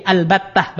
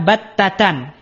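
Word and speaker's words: al-battah (البتة): Jadi ada dua cara baca, ya. Pertama, boleh dengan alif lam dan itu madhabnya Sibawaih. Boleh dengan membuang alif lam dan itu madhabnya Kufah. Boleh al-battah, boleh battatah, gitu ya, tanpa al.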